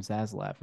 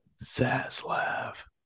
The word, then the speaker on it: Zaslav: You just say the name Zaslav, and that's it. – Zaslav.